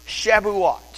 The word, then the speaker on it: Shavuot: It's from right to left, but it's pronounced Shavuot.